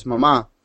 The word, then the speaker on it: mamá: And if you pronounce mom in Spanish, the word for mom is in Spanish is mamá. And if you say it with the emphasis on the wrong syllable, you end up saying just the nursing mothers.